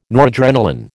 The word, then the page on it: noradrenalin: American English